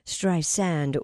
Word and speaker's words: Streisand: Barbara told NPR that Siri has been saying Streisand with a Z sound, not the correct pronunciation, which is Streisand with an S sound.